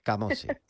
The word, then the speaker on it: Camozzi: Y por asegurarme, ¿he pronunciado bien tu apellido? – Camozzi, vale. Sí. ¿Con una Z o con dos? – Con dos Z.